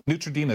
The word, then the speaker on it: Neutrogena: Neutrogena, is that how you say it? – Yes.